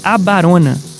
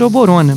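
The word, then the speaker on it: Abarona (Оборона): Essa é uma banda de punk rock russa chamada Grazdanskaya Abarona. Se escreve Oborona. Então podemos dizer Oborona, mas eu vi, liguei para um amigo meu russo, não entendi nenhuma palavra que ele disse, mas ele me disse Abarona.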